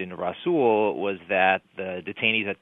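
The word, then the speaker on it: Rasul: Russell v. Bush. – Sure. – Essentially there, what did the court say? – Well, it actually pronounced Russell.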